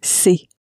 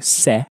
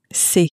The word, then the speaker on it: c'est: It's quite easy to pronounce. You find a lot of English speakers will often pronounce it as something more like se, but it's not exactly se, but more se.